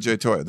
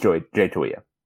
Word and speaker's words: Toia: We've always said Jay Toia. Did he change it when he went to UCLA? – So the trick with Polynesian names is just pronounce every letter.